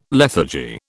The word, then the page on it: lethargy: British English